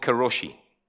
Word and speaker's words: karoshi: It's called karoshi, and I am pronouncing that spot on. Karoshi.